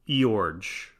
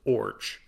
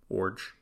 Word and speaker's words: Iorg: According to a 1985 New York Times article, the name is Danish and pronounced Orge. And that is also on Baseball Reference, just O-R-J, pronounced like orange with no N, Orge.